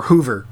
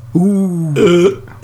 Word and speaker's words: oeuvre: We're going to be looking at not one, not two, not even five, but all 11 films in the Star Wars oeuvre, or Hoover. How do you say that? – Oeuvre. – Oh dear, yeah. – You have to say it like you're passing a kidney stone.